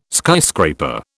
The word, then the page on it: skyscraper: British English